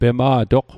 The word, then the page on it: pema'toq: Mi'gmaq